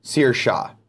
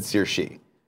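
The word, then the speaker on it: Saoirse: Industrial 86 says, "LOL, it's pronounced Searsha." I said Searshi.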